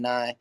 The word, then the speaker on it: Nye: And then it's spelled like Bill Nye the Science Guy or New Year's Eve.